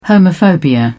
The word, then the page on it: homophobia: British English